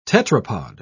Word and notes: tetrapod: British English

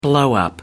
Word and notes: blowup: British English

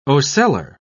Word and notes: ocellar: British English

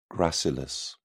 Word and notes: gracilis: British English

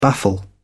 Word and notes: baffle: British English

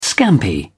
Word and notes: scampi: British English

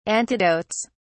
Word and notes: antidotes: British English

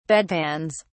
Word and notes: bedpans: British English

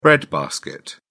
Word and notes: bread basket: British English

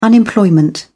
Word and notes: unemployment: British English

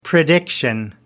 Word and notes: prediction: American English

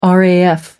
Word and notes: raf: American English

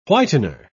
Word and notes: whitener: British English

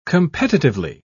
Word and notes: competitively: British English

